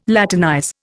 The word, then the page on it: Latinize: Indian